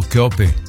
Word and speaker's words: Cheope: La compositora es Laura Pausini, pero con coautores como Giuseppe Dati y Cheope, se escribe Cheope pero es italiano, Cheope.